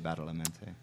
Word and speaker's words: Badalamenti: Directors like Angelo Badalamenti, how do you pronounce it? – I say Badalamenti.